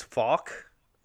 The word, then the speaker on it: Fouke: I found a few different pronunciations, but I think it's Falk, which is F-O-U-K-E.